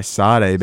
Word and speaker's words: Sade: By Sade, baby. – Sade, okay.